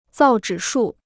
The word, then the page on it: 造纸术: zào zhǐ shù